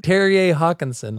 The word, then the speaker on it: Terje Håkonsen: Terje Håkonsen.